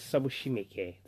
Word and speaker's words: Sabushimike: So that's Sabushimike.